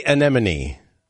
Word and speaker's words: anemone: No, you said enemy, like. – Sea anemone. – Sea anemone.